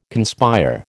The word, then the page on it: conspire: American English